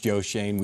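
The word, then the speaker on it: Joe Schoen: If Shane gets the job, and by the way, if we accomplish nothing else in this segment, we get one more person to understand it's pronounced Joe Shane.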